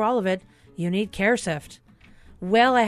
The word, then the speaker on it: CareSift: dot uh, it's a difficult name to pronounce, CareSift. Some people call us Care Assist or CareFit, but it's CareSift